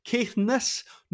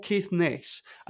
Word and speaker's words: Caithness: And can I just emphasise that it's pronounced Caithness, not Caithness. I know you would say Inverness, but that doesn't apply here. It's Caithness, not Caithness. You're going to offend Caithnesians if you pronounce it that way.